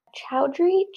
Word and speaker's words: Chowdhury: So one of his daughters, Anna Chowdhury, I don't know how to say it. – The first way sounded good. – Chowdhury.